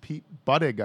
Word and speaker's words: Buttigieg: Pete Buttigieg, I think it's pronounced.